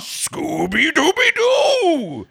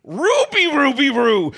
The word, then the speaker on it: Scooby-Dooby-Doo: But the one thing that he does not pronounce with an R is his name. He goes Scooby-Dooby-Doo. But Nathen has him saying Ruby-Ruby-Roo, which he does.